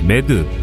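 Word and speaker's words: MAD: SLBM이 치명적이고 절대적인 핵무기의 대명사가 될 수 있는 이유는 3대 핵전력 중 상호 확증 파괴, 매드, 2차 보복 능력을 막고 있는 핵심 전력이기 때문입니다. 상호 확증 파괴 매드는 축약단 단의 발음을 그대로 읽으면 미쳤다라는 영어 단어 매드와 같은 발음인데요.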